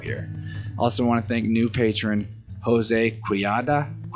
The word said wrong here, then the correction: Quijada: I'm sorry, I think I said it wrong the first time. Quijada. It's been a long time since I was in Spanish. I did look up a pronunciation for that to make sure I got it right, but it's been several minutes ago.